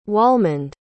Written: walmond

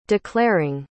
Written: declaring

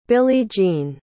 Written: billie-jean

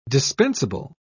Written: dispensable

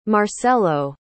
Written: marcello

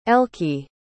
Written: elki

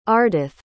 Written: ardath

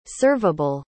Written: serveable

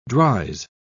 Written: dries

